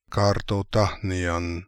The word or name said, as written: Kartotakhnian